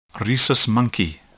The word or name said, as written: rhesus monkey